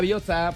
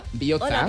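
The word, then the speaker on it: Bihotza: Hola, Biotza, porque Biotza. Es hola, corazón. Biotza. – Hola, Biotza. – Con TZ. Ojo, TZ. Biotza. Que no tenemos TH, tenemos TS, TZ y TX.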